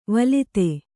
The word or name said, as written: ವೇಲ್ಲಿತ